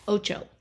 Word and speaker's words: ocho: But that crossed with one down, eight in Essen, which was acht, A-C-H-T. – Acht, yes. – Mm-hmm. And I find it interesting that in Spanish it's oco. – Ocho. – Ocho, which I know at least how to spell, if not pronounce.